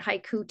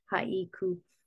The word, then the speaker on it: haiku: Like the word haiku in Japanese, we say it haiku, 2 syllables. In Japanese, you would say it with 3 syllables, haiku.